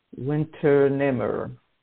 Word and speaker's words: Unternehmer: It's spelled U-N-T-E-R-N-E-H-M-E-R. And that's pronounced Winternehmer.